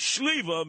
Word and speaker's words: Sliwa: I'm Polish, you know, because in Russian, Ukrainian, and in Polish, Sliwa, my last name, pronounced Shliva, means plum.